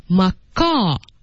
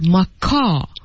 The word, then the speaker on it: Macaw: I don't even know if that exists actually, but Macaw. So you really should pay attention that you don't pronounce the W here. Macaw.